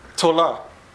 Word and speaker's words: tola: The word in Hebrew is tola. – ヘブライ語ではこの虫の名前はトラです。 – And two words that it rhymes with is one to rise up, to cover.